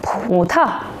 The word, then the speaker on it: 葡萄: 当葡和桃放在一起组成词语时，桃子要读作轻声，葡萄。